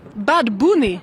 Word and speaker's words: Bad Bunny: And you taught me something, that you don't pronounce it Bad Bunny, you pronounce it how? – Bad Bunny. – Bad Bunny? – Yes. Okay. He also pronounced Bad Bunny. – Okay, so the correct way to say it is Bad Bunny.